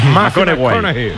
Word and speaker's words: McConaughey: Hay que ir corrigiendo la pronunciación. – McConaughey. Mike McConaughey.